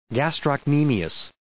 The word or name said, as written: gastrocnemius